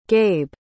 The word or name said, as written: gabe